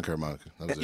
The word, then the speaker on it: Caramanica: Yeah, John Caramanica. – I don't even know if I said it right. – I think yeah, you got it right. John Caramanica.